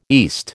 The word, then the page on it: east: American English